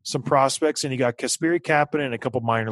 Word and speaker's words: Kasperi: Yep, Kasperi.